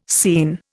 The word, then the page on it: scene: Indian